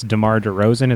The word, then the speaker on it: DeMar DeRozan: And I guess DeMar DeRozan, is that how you say his name? – Yeah.